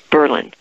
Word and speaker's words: Berlin: It's spelled like Berlin, but they pronounce it Berlin.